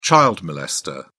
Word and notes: child molester: British English

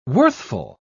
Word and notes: worthful: British English

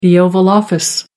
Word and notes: oval office: American English

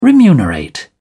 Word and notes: remunerate: British English

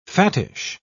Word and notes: fattish: British English

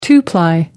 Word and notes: two ply: American English

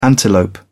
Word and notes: antelope: British English

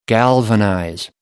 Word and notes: galvanize: American English

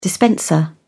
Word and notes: dispenser: British English

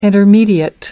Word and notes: intermediate: American English

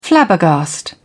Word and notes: flabbergast: British English

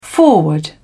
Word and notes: forward: British English

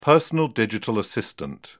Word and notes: personal digital assistant: British English

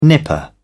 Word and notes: nipper: British English

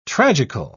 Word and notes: tragical: British English

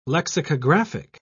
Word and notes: lexicographic: British English

